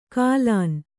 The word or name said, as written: ಕಾಲಾನ್